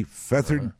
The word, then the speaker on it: Fethard: Now, that's not part of a pillow. That's not feathers. – Right. – It's Feathered is a town. It's spelled F-E-T-H-A-R-D, Feathered Tipperary in Ireland.